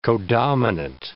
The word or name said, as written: codominant